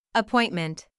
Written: appointment

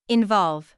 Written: involve

